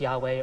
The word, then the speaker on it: Yahweh: Whenever you see Lord, all caps, in our English translations, it is God's personal name, Yahweh, Y-H-W-H, which we pronounce Yahweh or Jehovah sometimes.